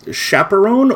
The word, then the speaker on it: chaperon: Chaperone or chaperonne or chaperon? – Chaperon. – Chaperon. – French.